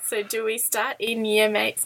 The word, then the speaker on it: Yamate: and the closest station is Yamate, which is spelled Y-A-M-A-T-E. So he says the easiest way to remember it is that it looks like your mate.